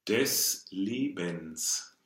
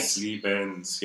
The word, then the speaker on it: Lebens: The last syllable of that, the Lebens, is very, very close to the des, right? – Des Lebens, yeah. – so that the schwa in German ends up being more along the lines of the open e.